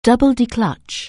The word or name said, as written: double-declutch